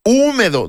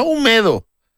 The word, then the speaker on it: húmedo: El hashtag supremo húmedo, no húmedo. – Húmedo. – ¿Eso qué significa? Húmedo, que trae un acento en la U. – Sí, ¿no? Porque es esdrújula.